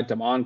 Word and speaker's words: Damon: They have a guy, Damon Clowney. He's related to Jadeveon Clowney. It's spelled like demon.